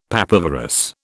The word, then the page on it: papaverous: British English